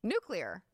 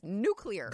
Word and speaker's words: nuclear: Nuclear. That's how it's spelled. – Nuclear. – There's not another U in there. It's nuclear.